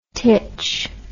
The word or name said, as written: titch